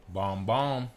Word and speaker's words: bonbons: Bomb bomb. Right? So that's how you say it, bomb bomb.